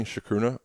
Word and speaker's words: chacruna: Am I saying chacruna wrong? – No. I believe that's the right pronunciation of it.